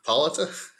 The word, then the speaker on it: Hippolyta: Hippolytia is back. How do you say her name? Wonder Woman's mom. – Hippolyta? – She just died, spoilers, in Trial of Amazons.